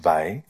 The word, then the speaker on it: Weil: She was her surname is spelt W-E-I-L, but it's pronounced Weil.